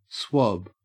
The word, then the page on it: swab: Australian English